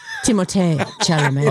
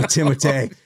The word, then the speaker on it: Timothée Chalamet: Instead, they made one Timothy Chalamet. – Timothée Chalamet. – Oh, Timothée.